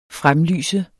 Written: fremlyse

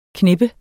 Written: knippe

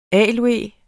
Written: aloe

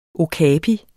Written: OKAPIEN

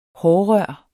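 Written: HÅRRØR

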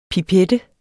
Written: PIPETTENS

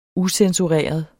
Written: ucensureret

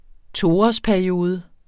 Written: toårsperiode

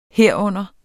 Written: herunder

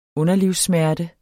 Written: underlivssmerte

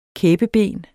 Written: kæbeben

